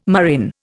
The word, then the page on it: murrain: Indian